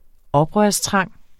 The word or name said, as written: oprørstrang